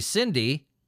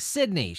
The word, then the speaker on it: Sydney: Researchers at the University of Technology, Sydney? Why do I say that wrong? Sydney.